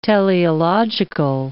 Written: teleological

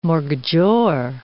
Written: mortgagor

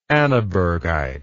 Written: annabergite

